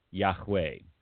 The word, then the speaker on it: Yahweh: I can't exactly remember what he said, but it was I am, meaning Yahweh in Hebrew is what it is, how you pronounce it.